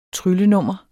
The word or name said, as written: tryllenummer